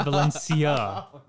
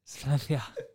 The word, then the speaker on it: Valencia: Oh, I said it wrong. I'm so sorry. I said Valencia. Valencia. Can I do it again? – Yeah.